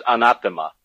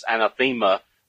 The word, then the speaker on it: anathema: That's a Greek word, anathema. Sometimes anathema, some Americans pronounce it anathema, but it's slightly off. It's anathema.